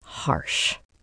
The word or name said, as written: harsh